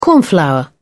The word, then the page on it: cornflour: British English